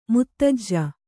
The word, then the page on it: ಮುತ್ತಜ್ಜ: muttajja